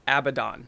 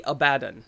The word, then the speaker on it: Abaddon: And everyone I know says Abaddon. – Uh-huh. – It's actually Abaddon.